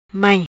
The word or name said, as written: Mai